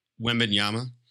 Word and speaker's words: Wembanyama: And, you know, whatever their calculation is and the extra 4 or 5% to get Wembanyama. Did I say it correctly? – Wembanyama. – Okay, close enough.